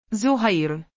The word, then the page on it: zaheer: arabic